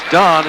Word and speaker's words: Dan: The score for Dan Grecu, pronounced Don in Romania, 9-8-5.